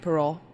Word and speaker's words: Perrault: No, it's spelled differently, like the French way. – It's like Perrault.